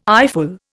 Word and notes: eyeful: Indian

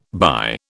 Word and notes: by: British English

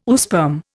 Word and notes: oosperm: Indian

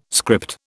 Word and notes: script: British English

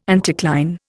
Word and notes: anticline: Indian

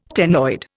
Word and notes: ctenoid: Indian

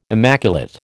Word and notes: immaculate: American English

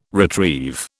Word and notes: retrieve: British English

